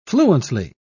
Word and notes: fluently: British English

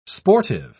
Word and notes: sportive: British English

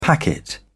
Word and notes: packet: British English

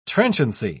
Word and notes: transiency: British English

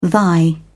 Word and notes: thy: British English

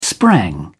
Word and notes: sprang: British English

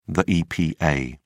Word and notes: epa: British English

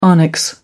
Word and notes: onyx: American English

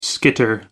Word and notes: skitter: American English

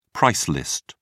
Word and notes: price list: British English

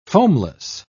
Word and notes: foamless: British English

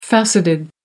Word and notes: faceted: American English

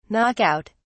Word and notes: knock out: British English

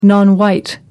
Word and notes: non white: American English

